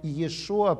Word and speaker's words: Йешуа: согласно профессорам Давиду Флюсеру и Шмуэлю Сафраю, имя Йешуа произносилось галилеянами первого века как Йешу.